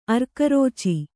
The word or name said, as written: ಅರ್ಕರೋಚಿ